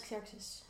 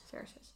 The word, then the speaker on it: Xerxes: Uit, eh, hoe spreek je dat uit? Xerxes? – Uit eh, Xerxes. Xerxes.